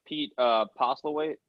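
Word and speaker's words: Pete Postlethwaite: We got to shout out Pete Postlewaite.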